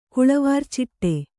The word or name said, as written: ಕುಳವಾರ್ಚಿಟ್ಟೆ